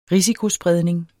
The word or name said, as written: risikospredning